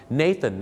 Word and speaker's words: Nathan: Nathan